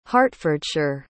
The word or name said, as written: hertfordshire